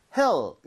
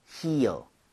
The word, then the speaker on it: heel: 얘는 모음이 이렇게 연결이 돼 있으면서 입 모양을 좀 벌려야죠, 그렇죠? – 네. – So 얘는 그냥 heel 이렇게 하는 게 아니라 heel, heel.